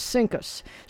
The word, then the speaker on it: CINCUS: But when they pronounced it, it was CINCUS.